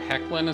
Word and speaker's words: Hoechlin: So I'm not trying to take the wind out of anybody's sails if anyone's happy about the fact that Tyler Hoechlin is, because apparently that's how the guy's name is pronounced. I believe it was Rebecca Johnson who corrected me on that.